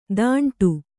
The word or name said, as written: ತಾಂಟು